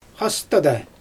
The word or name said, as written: xastada